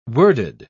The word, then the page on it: worded: British English